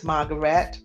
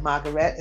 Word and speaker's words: Margarette: Again, my name is Margaret Farmer. And Margaret is spelled M-A-R-G-A-R-E-T-T-E. And everybody knows how to spell Farmer, I'm sure. F-A-R.